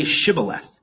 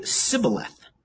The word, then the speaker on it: Shibboleth: If they said no, then they would say to him, then say Shibboleth and he would say Sibboleth, but you know, for he could not pronounce it right.